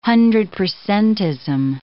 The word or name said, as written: hundred-percentism